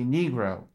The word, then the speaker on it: Negro: N-I-G-R-A. Judge Motley stands up, objection. It's not pronounced Nigra, N-I-G-R-A. It should be Negro, N-E-G-R-O.